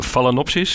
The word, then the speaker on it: Phalaenopsis: Want jullie zeggen, we zijn de complete partner voor de handel en teelt van de Phalaenopsis, zeg ik het goed.